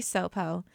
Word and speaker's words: Sopow: My name is Kitty Sopo, and that's spelled S-O-P-O-W, and I'm a write-in candidate for the Sitka Assembly.